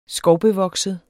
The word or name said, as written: skovbevokset